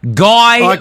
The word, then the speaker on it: Guy: I've learned over the years that even though the name's spelled G-U-Y, and I used to be like that typical Australian, Guy Manoukian. But then I started hearing the right pronunciation, which is Guy.